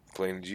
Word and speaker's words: Planegea: Planegia, I believe, is how you pronounce this. – Planegia? – Planegia.